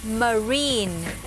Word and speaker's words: marine: นี่คำนี้ไม่ได้อ่านออกเสียงรากยาวจนเกินไปนะคะแต่ว่าจะอ่านออกเสียงสั้นๆว่า marine คำนี้นะคะ marine